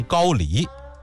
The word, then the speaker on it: 高丽: 还有一回啊，武则天的另外一个男宠张易之，张易之的兄长叫张同修，张同修在宴会上就拿这个杨再思啊开涮寻开心，说杨公的脸好像高丽人。我知道这个词儿正经的读音应该是读成高丽，也就是朝鲜历史上那个王朝啊。